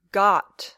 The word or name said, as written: got